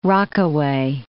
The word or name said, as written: Rockaway